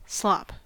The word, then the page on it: slop: American English